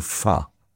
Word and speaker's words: pho: Well, it's called Pho, actually. But every time I say Pho, everyone goes, what? So I know it's called Pho, but I'm going to call it Pho because it's spelled P-H-O.